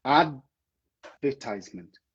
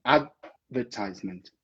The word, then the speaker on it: advertisement: O D, ele é muito sutil nessa palavra. Ele é muito sutil, é como se a gente pronunciasse ele para dentro. Eu vou falar de novo, queria que você prestasse atenção na minha língua, tá? Advertisement. É para dentro.